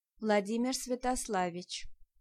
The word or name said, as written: Vladimir